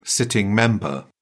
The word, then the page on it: sitting member: British English